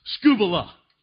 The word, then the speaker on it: skubala: The Greek word is in English is pronounced skubala.